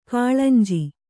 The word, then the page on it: ಕಳೆಂಜಿ: kaḷenji